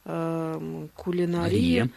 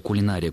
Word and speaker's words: кулинария: Ну, мы говорили в прошлый раз о том, что слово «кулинария» – «кулинария» на самом деле сейчас рассматриваются как акцентологические варианты, то есть и то, и другое правильно. Можно объяснить, откуда возможно двойное ударение. «Кулинария» – более традиционная форма, связана с сохранением ударения в однокоренном слове «кулинар». «Кулинария» – это форма, которая более соответствует историческим, то есть подстраиванию под аналогию.